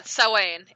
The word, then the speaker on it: Samhain: So actually, it's Samhain. It looks like Samhain. – Is it pronounced Samhain? Okay, that's what I voted for too. – Yeah, yeah. – It looks like Samhain, but I guess in Celtic it would be Samhain.